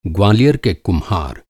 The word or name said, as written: ग्वालियर